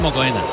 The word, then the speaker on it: Mokoena: Temojo Mokone. – Mokone es Mokoena.